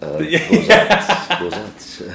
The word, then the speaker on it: Beaux Arts: That is how I pronounce it, right? Beaux Arts. – Well, they probably say Beaux Art, but Beaux Arts.